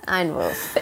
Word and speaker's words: Einwurf: Like, wait, how do you say it though? Einwurf? – What is it? Einwurf. – Einwurf? Einwurf. Oh gosh, I have the worst American accent. I'm Einwurf. Oh, well, Einwurf. Exactly, perfect.